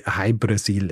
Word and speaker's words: Ui Brasil: Jahrhundert jetzt, wenn in Irland drüber geschrieben wird, dann wird gerne mal die Insel zum Beispiel Brasil geschrieben oder O-Brasil oder eben Ui Brasil, was ausgesprochen quasi so viel wie High Brasil ist.